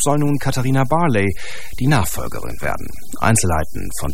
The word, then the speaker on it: Barley: Also mein Vater ist Brite, also man spricht es Englisch aus, wenn man es korrekt aussprechen möchte, also Barley.